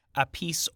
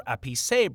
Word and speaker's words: Appice: Because they both pronounce it differently. Eventually, the Appice or Appice